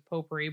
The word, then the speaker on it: potpourri: but it's potpourri. But I, in my head, you said it right.